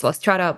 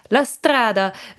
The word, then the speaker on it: La Strada: And I feel like going back to like, not to harp too much on the music and like the choice that it's La Strada, but La Strada, if we're still trying to be good about pronouncing things.